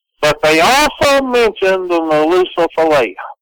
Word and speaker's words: Nalusa Falaya: But they called it, it's a two-word name, the Nalusa Falaya. It's spelled N-A-L-U-S-A, Nalusa, which usually means man, and Falaya, F-A-L-A-Y-A.